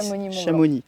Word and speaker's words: Chamonix: pas Chamonix. – On le X, on le prononce pas.